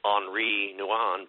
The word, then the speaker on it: Henri Nouwen: I remember there's a gentleman that spoke, and I'm going to butcher his name, right, because we say it in America, Henry Nouwen. Mm-hmm. I think it's Henri Nouwen.